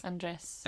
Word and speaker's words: Andres: well, it's like Andre, but with an S. Mm-hmm. Andres? – Andres.